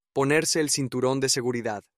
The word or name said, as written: ponerse el cinturon de seguridad